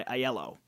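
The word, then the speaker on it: Iello: This is a Steve Finn game, and it's published by Aiello, or Yellow. – Or Yellow, whatever. Sorry, Yellow. However you want to pronounce it, I guess. – I usually say Yellow, and I just didn't this time.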